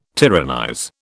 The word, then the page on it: tyrannize: British English